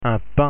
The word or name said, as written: pain